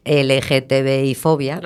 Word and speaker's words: LGTBI-fobia: lo digo bien, LGTBI-fobia. – Lo has dicho perfecta.